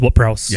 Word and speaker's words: Ward-Prowse: Just Ward-Prowse. – Yeah, like Ward-Prowse.